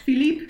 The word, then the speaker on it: Philippe: Philippe. Philippe, pronounced Philippe. in French.